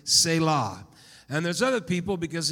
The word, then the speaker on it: Selah: So I'm going to go with what I see on the Hebrew pronunciation, which is Selah. I like that part. You're supposed to put emphasis on the latter part, not the first part.